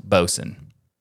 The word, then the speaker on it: Bosun: Just to be clear for the audio listeners of Twill, it's spelled like boatswain, but pronounced bosun.